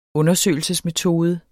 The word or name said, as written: undersøgelsesmetode